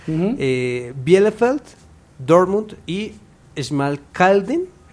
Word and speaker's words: Schmalkalden: La S al principio siempre va a sonar Ch. – Entonces, sería Schmalkalden. – Perfecto.